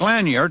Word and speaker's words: Lanier: And Lake Lanier, I guess that's the way it's pronounced, L-A-N-I-E-R, Lanier, furnishes the water for Atlanta, Georgia.